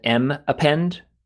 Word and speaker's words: mappend: In Haskell, for historical reasons, it was called M append, and people pronounce it mappend. It's monoid append, and that's because they had name collisions with other things.